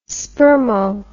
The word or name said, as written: spermo